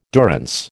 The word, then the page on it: durance: American English